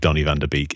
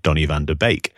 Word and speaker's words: Donny van de Beek: But also to very politely point out that my pronunciation of Donny van de Beek is incorrect. And it is, of course, Donny van de Beek.